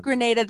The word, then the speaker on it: Grenada: I was corrected by the Grenada tourism board lady at a meeting once. She's like, it's Grenada. – Oh, well, Grenada, the Spice Island.